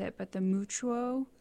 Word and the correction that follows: Mutua: Mutua. – Mutua. Thank you.